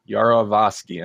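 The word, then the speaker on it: Yarovesky: This was written by Mark Gunn and Brian Gunn, and directed by David Yarovsky. Yarovsky, and I think I said it right the first time.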